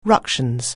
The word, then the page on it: ructions: anglais